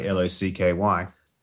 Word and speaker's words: Lachy: If you want people to pronounce it Lachy, like Locky, then your name should be Lachy.